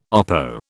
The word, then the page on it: oppo: British English